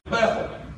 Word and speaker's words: Bethel: Now, we've got a lot of churches these days named Bethel, and that's the way we usually pronounce it, Bethel. But it's really Bethel. It's because it's the house, Beth, the house, and El meaning God Almighty. So Bethel, that's what it is, the house of God.